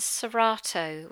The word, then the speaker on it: Cerato: So the first essence that I'd like to talk to you about is Serrato, which is spelt Cerato.